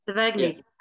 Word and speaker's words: Sevigny: And also, is it Chloe Sevigny? Is that how you say it? I'm terrible at pronouncing. – Yeah, I'm not sure how to pronounce this. – Sevigny. Yeah. So Chloe Sevigny.